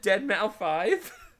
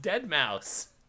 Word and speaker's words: Deadmau5: Wait, you pronounce it Deadmau5? – Is that not how it's pronounced? – I thought it's supposed to be pronounced Dead Mouse.